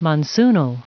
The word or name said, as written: monsoonal